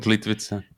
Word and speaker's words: Plitvice: I love the Plitvice. Is that they pronounce it? The national park they have there? I don't know how to pronounce it. – Plitvice.